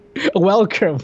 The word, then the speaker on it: Welker: That's funny, but um, yeah, in this you've got uh, Frank Weller, which uh, what Welker? Why can't you know? We spent like three minutes talking about him before we went mm-hmm. – live, and I mispronounced his name like seven times. You corrected me each time, and I don't respect you.